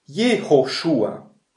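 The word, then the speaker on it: Jehoshua (יהושע): Und jetzt Josea, ausgesprochen auf Hebräisch, Jehoshua.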